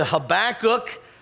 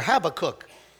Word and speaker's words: Habakkuk: I invite you to open up to Habakkuk.